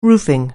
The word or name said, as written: roofing